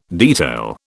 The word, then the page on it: detail: British English